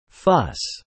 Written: fuss

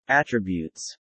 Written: attributes

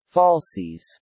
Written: falsies

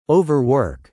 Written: overwork

